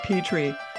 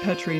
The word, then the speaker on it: Petrie: Well, my husband pronounces his name Petrie, but that would be in Germany. But we're here in America, so it's Petrie now.